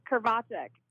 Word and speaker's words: Krivachek: You're kind of close. – How would you say it? – So it's Krivachek.